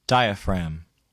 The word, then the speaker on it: diaphragm: The letter G is not pronounced in the following words. Read them to note their spelling and repeat them during the pauses. – Phlegm. Diaphragm.